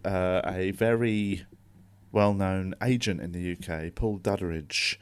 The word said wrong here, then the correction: Duddridge: I don't know why I added a sort of syllable into his name there. It's Duddridge. but he was basically the agent for some of your favorite comedians, biggest comedians in the UK, Michael McIntyre, Rob Brydon, loads.